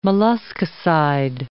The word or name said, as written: molluscicide